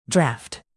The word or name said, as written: draft